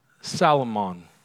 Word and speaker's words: Salmon: it's spelled Salmon, but it's pronounced Salomon.